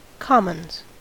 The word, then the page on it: commons: American English